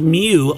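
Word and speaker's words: miu: And your final fact, in ancient Egypt, the word for cat was actually pronounced mew or meow.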